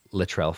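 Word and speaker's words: Latourell: Littrell Falls? Is that how you say it? – Uh-huh.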